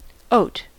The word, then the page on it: oat: American English